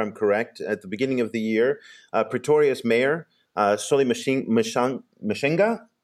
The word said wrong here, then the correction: Msimanga: Mzimanga, okay.